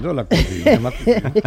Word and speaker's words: la cuisine de ma cousine: La cuisine de ma cousine. – Ah, lo he dicho bien. – Lo has dicho. La cuisine de ma cousine.